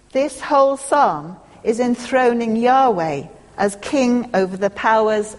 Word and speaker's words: Yahweh: But what it is important to realise is the significance of the word translated here as Lord, which would originally have been written down in our script as YHWH, which we generally pronounce Yahweh.